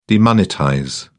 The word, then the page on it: demonetize: British English